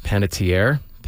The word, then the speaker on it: Panettiere: Panettiere. Hayden, this is what a sad story. He's 36. – Hayden Panettiere passed away on Sunday.